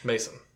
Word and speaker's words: Mazin: So that's Craig Mazin, I think is how you pronounce his name. – Mason. – Mason? – Pretty sure that's how I heard it pronounced.